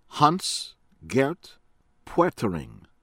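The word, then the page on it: Hans-Gert Pöttering: American English; from Germany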